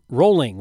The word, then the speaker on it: Rowling: Rowling, which I learned is pronounced Rowling and not Rowling, has weighed in. You know that? J.K. Rowling? – Really? – That's how she pronounces it. It's like a tennis player.